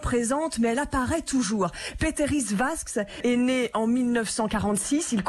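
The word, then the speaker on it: Pēteris Vasks: il s'appelle Peteris Vask, prononcez toutes les lettres, hein, donc Peteris Vasks,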